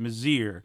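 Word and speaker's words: Mizeur: It's M-I-Z-E-U-R. – Yes. – It looks like the French Mazeer, but it's she pronounces it Mazeer.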